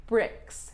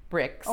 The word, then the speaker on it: Brix: BRIX. B-R-I-X. And I am pronouncing that right. BRIX. – Oh, there might be. – Well, like, you know, Grand Prix, for example, the I-X in French is pronounced like a long E. – Okay. – But it really is BRIX. B-R-I-X.